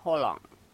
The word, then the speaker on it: Haaland: Y Nelson Castro le preguntó cómo se dice Haaland, cómo se pronuncia. Y le dijo que se pronuncia Holland. – Holland. – Holland. – Holland, ¿cómo anda? Bien. – La primera es una O, O, porque son dos.